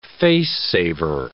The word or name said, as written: face-saver